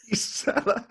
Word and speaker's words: Gisela: So I always say, just think of geese when you get it right. It's Gisela. – Gisela.